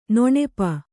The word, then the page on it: ನೆನಪು: nenapu